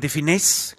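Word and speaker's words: de Funès: Und Louis de Finet, oder de Finesse, glaube ich. – De Finesse wird ausgesprochen.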